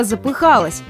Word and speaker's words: запыхался: Несмотря на то, что некоторые онлайн-словари приравнивают два эти ударения, все же существует норма, которая предписывает нам ставить ударение не на корень, а на суффикс. То есть правильно будет сказать «я запыхалась» и никак не запыхалась.